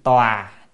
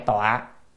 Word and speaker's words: tòa: Chữ này có thể đọc là tòa hay tọa, tọa hay tòa cũng được, mà tòa thì nó hay hơn, tòa thì nghe nó có vẻ hay hơn đúng không ạ?